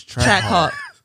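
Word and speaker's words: Trackhawk: So for all y'all people who got that big body Cadillac truck, that Ford Explorer, that Tahoe, that Hummer, the Trap Hawk. Am I saying it right this time? – No. – Track? – No, yes. Track Hawk.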